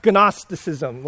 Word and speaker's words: Gnosticism: Actually, you pronounce it wrong. It's pronounced Gnosticism. Like in the ancient Greeks, you're supposed to pronounce the G, so it's Gnosticism. It's obviously where we get our word knowledge.